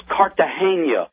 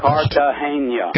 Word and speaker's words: Cartagena: Pronounced Cartagena. Can you say that with me one time? – Okay. – Cartagena. – Cartagena. Thank you so much.